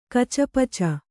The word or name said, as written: ಕಚಪಿಚಿ